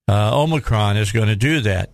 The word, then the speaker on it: Omicron: Did you watch the president yesterday and see what he had to say about COVID and about Omicron? The proper pronunciation, by the way, is Omicron. I don't know where the Omicron came from, but that's what they started calling it.